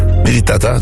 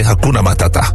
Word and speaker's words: Hakuna Matata: Militata? Nee, Hakuna Matata.